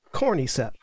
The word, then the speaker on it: Cordyceps: And yes, America America mispronounced that. – Cordyceps.